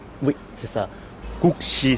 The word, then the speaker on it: Guksi: Et Gukshi, ça s'écrit G-U-K-S-I. – C'est ça, oui. Gukshi.